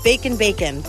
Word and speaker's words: Bacon Bacon: We are at the site of the former Devil's Den, which is soon to be a new restaurant called Bacon Bacon, but it's spelled like baking bacon, but bacon like with an N, which I love.